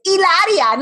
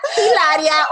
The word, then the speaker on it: Hilaria: I was born in Boston, and it's Hilaria, not Hilaria or Hilaria.